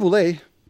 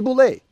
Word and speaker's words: Bule: I just want to say, look, the word BULE, to me, is just a neutral word. It doesn't really mean anything. It just rhymes with the word okay. That's it. Okay, BULE.